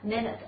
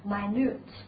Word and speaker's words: minute: 同样的道理呢，它也可以做形容词，表示细微的、细小的，但这个时候的发音呢，可不就是 minute，而是读成 minute，啊 minute，大家注意这样的区别。